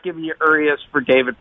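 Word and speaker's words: Urías: Urias, is that the Dodgers pitcher? Did I pronounce it correctly, Tim? – Yes, he's pitching tonight, Stugatz.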